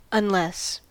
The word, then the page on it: unless: American English